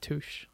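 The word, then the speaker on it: touché: Touche. – Actually, it's pronounced touche.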